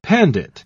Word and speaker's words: pandit: And it comes from an old Sanskrit word for a Hindu scholar who's learned in philosophy and religion. And that word is pronounced like this. – Pandit.